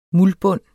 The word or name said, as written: muldbund